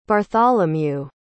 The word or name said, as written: bartholomew